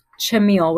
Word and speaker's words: Chmiel: Chmiel, with a hard ch.